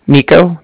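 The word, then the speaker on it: Niko: Nico. That's N-I-K-O. And then calling with the Bank of Cardiff. And Cardiff is spelled C-A-R-D-I-F-F, as in Frank Frank.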